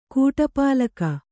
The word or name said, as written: ಕೂಟಪಾಲಕ